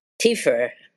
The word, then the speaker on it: Tiefer: Tiefer.